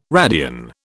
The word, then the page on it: radian: British English